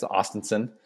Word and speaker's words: Ostenson: It's like Austin, Texas. Ostenson.